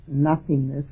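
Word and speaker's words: no-thingness: It's not nothingness, which is usually the explanation, but it's spelled with a hyphen, no thingness, and which is a more literal translation.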